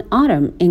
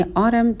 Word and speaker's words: autumn: One autumn in college یعنی یک روز پاییزی توی دانشکده. به تلفظ کلمه autumn دقت کنین. Autumn تلفظ آمریکایی هست. Autumn تلفظ بریتیش هستش.